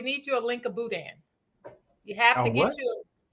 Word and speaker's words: boudin: No, it's called boudin. Boudin. B-O-U-D-I-N. Some people spell it D-A-I-N.